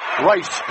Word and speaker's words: Reiss: Spelled R-E-I-S-S. Pronounced Rice.